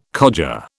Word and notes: codger: British English

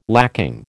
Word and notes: lacking: American English

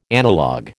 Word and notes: analogue: American English

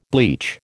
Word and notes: bleach: American English

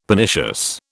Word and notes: Pernicious: British English

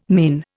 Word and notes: mane: Indian

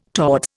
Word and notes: towards: Indian

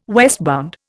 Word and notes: westbound: Indian

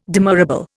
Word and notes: demurrable: Indian

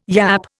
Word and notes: yapp: Indian

